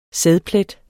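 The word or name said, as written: sædplet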